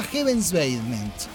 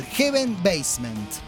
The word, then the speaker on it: Heaven's Basement: Heaven's Basement. O sea, los tipos venían de Inglaterra, no los conocía nada, nadie de nadie. Repito el nombre porque lo dije mal. Heaven's Basement, el sótano del cielo.